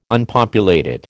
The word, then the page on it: Unpopulated: American English